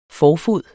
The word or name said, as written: forfod